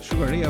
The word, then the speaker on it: Sugar Ray: Sugar Ray é o correto, a pronúncia correta.